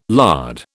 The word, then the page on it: lard: British English